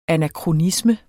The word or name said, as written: anakronisme